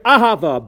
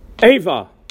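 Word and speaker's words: Ahava (אהבה): And in this tshuva, Rav Asher Weiss cites a Medrash Rabban Shir HaShirim, Amar Rabbi Acha, someone who recites the word Ahava by saying Eva, or the word V'ahavta by saying V'ayavta, or Ahava, Ayava, Ahavta, Ayavta.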